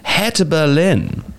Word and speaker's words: Hertha Berlin: Seb, the first one, Hertha Berlin.